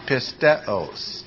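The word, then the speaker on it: pisteos (πίστεως): First of all, where is the accent? On the first syllable, is it not? And the word is pisteos. Pisteos.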